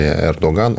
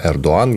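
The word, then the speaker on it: Erdoğan: Мы говорим про Турцию в преддверии президентских выборов, где 50-50, 50 на 50, непонятно, выглядит, выиграет ли Эрдоган, он же Эрдоан, если говорить по-турецки, потому что гетом не читается.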